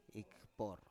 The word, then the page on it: ikpor: Biak